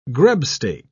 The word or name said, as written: grubstake